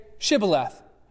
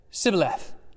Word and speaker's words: Shibboleth: And when he said no, they said, okay, well then say Shibboleth. And he said, Sibboleth, for he could not pronounce it right.